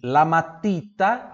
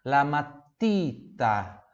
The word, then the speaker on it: matita: lamatita, Aqui também, ó. Cuidado. Cuidado com a pronúncia do ti. Novamente, igual fundo tinta, lamatita, lamatita, Pronúncia pura do T ali, tá bom?